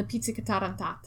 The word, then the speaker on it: pizzica: The pizza cantata tata. – Pizzica. – Pizzica. – Yeah, pizzica.